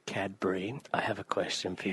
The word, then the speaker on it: Cadbury: Did you fuck up saying Cadby? – Yeah, I did. And I went to say Cadby, but I said it right.